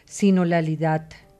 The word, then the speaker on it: sinodalidad: Y es un poco lo que también planteaba y le pedía ahora a Vicente Durán que profundizara con la sinodalidad, si lo pronuncié correctamente.